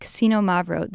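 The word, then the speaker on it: Xinomavro: And while I was there, I made a magical discovery with Casino Mavro. This is a very hard to say grape variety. It starts with an X, but you say it like Casino Mavro.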